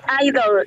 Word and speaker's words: Idle: Se llama Idol, y se escribe e, I-D-L-E, Idle, ¿Ya?